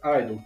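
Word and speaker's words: (G)I-DLE: E aí, depois eu vi que, na verdade, pronuncia apenas idol, que o G é mudo.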